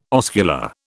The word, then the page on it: oscula: British English